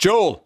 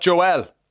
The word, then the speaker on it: Joel: And she knows, she says, it's pronounced Joel, not Joel.